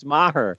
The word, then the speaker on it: Meyer: It's Maher, but we like Meyer.